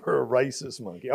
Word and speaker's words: rhesus: A racist a monkey? Rhesus. – Oh, a, a rhesus monkey.